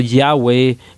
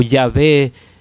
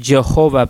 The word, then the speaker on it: Yahweh: de que el nombre no, era pronunciado Yahweh o Yahvé, sino Jehová. Similarmente a lo que decimos Jehová, pero no es Jehová porque no hay J en, el hebreo original este, o, en el griego, creo.